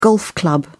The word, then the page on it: golf club: British English